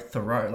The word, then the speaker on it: Thoreau: So it's Henry David Thoreau, and his quote, Thoreau, like as in I'm throwing the ball.